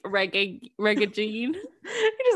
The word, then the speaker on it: Jean: Je- Jean is pronounced like John, but with a French accent.